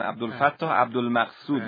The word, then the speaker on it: عبدالفتاح عبدالمقصود: جناب استاد پیشوایی، البته اسم رو دارن درست تلفظ نکردن، عبدالفتاح عبدالمقصود منظورشون بود.